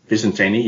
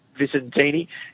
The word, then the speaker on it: Vicentini: And Vicente, I think, is that how you say it? Vicente, the ruckman? – Vicentini, yeah. – Vicentini.